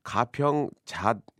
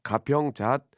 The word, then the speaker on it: 가평 잣: 자, 그 휴게소마다의 어떤 그 특징과 예, 또 먹거리에 대해서 한번 얘기를 나누고 있는데 887 하나님께서는 이제 가평 휴게소에 가평 잣 예, 이게 이제 발음을 세게 하면 안 됩니다. 가평 잣 아, 비빔밥 꿀맛입니다.